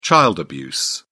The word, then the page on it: child abuse: British English